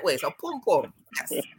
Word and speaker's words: pum pum: So i got to teach you how to say it. Pum. Say pum. Pum. Pum pum.